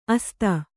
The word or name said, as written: ಅಸ್ತ